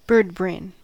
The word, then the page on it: birdbrain: American English